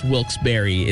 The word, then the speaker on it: Wilkes-Barre: I assume you're talking about Wilkes-Barre, but I'm pretty sure it's pronounced Wilkes-Barre is the correct pronunciation.